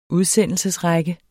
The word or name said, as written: udsendelse